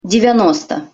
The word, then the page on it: devjanosto: Russian